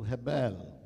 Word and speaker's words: Hebel: It is pronounced in the Hebrew, Hebel, which can be translated many ways, and I'll give you the words.